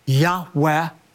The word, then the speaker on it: Yahweh: covenant name that he revealed to Moses most scholars believe it's pronounced a reverent breathy Yahweh is translated in our English Bible simply as Lord, capital L, capital O, capital R, capital D.